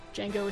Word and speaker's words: Django: If you saw Django Unchained, you remember that there's a scene where Django is standing at a bar with a guy with a foreign accent, and he says, "My name's Django, D is silent."